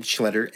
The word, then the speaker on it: Schletter: Anyway, it's Evan, E-B-A-N, Schletter, S-C-H-L-E-T-T-E-R.